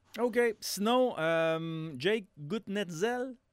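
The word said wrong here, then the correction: Guentzel: Ça se dit Genzel. – Ça s'écrit Gutnetzel.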